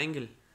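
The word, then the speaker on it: Engels: Es Engel. Engel, Engel. ¿Cómo se pronuncia? – Ajá, Engel.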